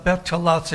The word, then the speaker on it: Betulah: I'll spell it for you, B-E-T-U-L-A-H. B-E-T-U-L-A-H, Betulah.